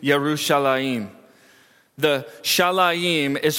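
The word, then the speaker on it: Yerushalayim (ירושלים): The place named Jerusalem, pronounced Yerushalayim in Hebrew, is a combination of two words.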